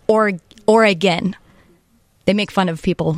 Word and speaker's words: Oregon: So how do the locals say it? – Yeah, it's Oregon.